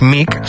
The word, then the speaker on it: Meek: Ella es Mick, se escribe M-E-E-K,